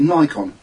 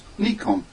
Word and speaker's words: Nikon: Now, that's a Japanese word, but they pronounce it Nikon. It's wrong. It's Nikon.